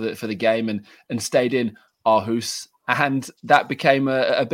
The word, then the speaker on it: Aarhus: But if you want to rehearse it, it's Aarhus. – Aarhus.